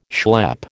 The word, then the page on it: schlep: American English